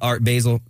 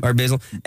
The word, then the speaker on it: Art Basel: Anyway, you know, I mentioned flying back from Argentina and reading the magazine about Art Basel. Did I say it right? – Yeah. – Art Basel.